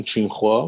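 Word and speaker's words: Tsinghua: دانشگاه تیسینکوا یا به پرونسیشن خودشون چینخوا با تلفظ خودشون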